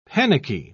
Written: panicky

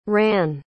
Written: rahn

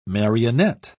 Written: marionette

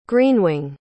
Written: greenwing

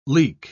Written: leak

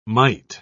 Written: mite

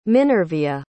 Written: minervia

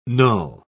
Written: null

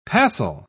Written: passel

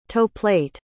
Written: toeplate

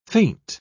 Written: faint

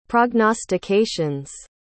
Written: prognostications

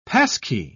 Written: passkey

